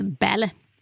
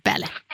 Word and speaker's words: Balle: Ja, och Solveig heter, det stavas B-A-L-L-E, men uttalas Belle. Belle.